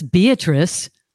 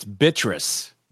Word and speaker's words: Beatrice: Like 3 or 4 years ago, I guess he and James Blunt, who's another British singer, and here we go, Princess Beatrice, who just got married. I guess they were wasted. What? – Rewind in your mind. That's not how you say it. It's Beatrice.